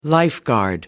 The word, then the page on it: lifeguard: American English